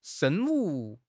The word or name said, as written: 生物